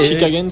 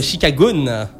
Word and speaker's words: Chicagoans: C'est pas Chicago. Enfin, OENS. C'est ça. Non, mais j'arrive pas à le prononcer en fait. – Les quoi? – Chicagoans. – Ce sont les Chicagoans. – Les Chicagoans. – Et c'est quoi l'orthographe? – Eh ben, ça dépend. En anglais, c'est G-O-A-N. Et en français, c'est G-O-N-E-S.